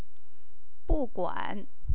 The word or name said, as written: buguan